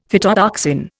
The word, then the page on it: phytotoxin: Indian